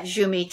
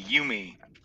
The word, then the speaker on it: Jumi: and DZTA winner from 2016 and currently at Jumi Cheese. Did I say that right, Jumi? – It's like you and me, Yumi.